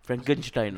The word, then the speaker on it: Frankenstein: Frankenstein. – No, that's the correct pronunciation.